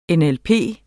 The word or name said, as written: NLP